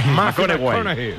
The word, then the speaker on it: McConaughey: ¿Mateo McConaughey? – Sí, sí, sí, sí, sí. – Me fichó, le gusta. Hay que ir corrigiendo la pronunciación. – McConaughey.